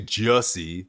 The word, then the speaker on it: Jussie: At least I'm calling him Jussie, but it's actually Jussie. Like rhymes with hussy, you know.